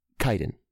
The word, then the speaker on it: chitin: It's pronounced chitin.